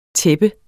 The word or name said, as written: tæppe